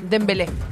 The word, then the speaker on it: Dembélé: ¿Cómo se pronuncia? ¿Embelé? Dembélé.